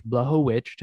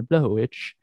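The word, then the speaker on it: Błachowicz: Uh, main event, Jan, Bla- the guy, the last name I just learned, thank you very much, Blachowicz, I had to get the pronunciation, Blachowicz, versus Glover Teixeira and uh, sorry.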